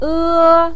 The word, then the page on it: e: 1